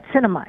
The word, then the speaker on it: Sinema: Sinema. It's pronounced like the cinema.